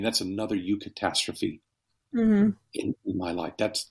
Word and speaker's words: eucatastrophe: But Tolkien coined a term, eucatastrophe,